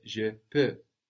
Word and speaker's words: je peux: So that's where we get je peux from, P-E-U-X. And again, we have the silent X on the end, just like in je veux, I want.